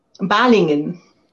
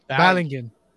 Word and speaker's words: Balingen: Now, uh, Balingen? – Balingen. Balingen. yeah, go. perfect.